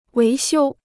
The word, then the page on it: 维修: wéi xiū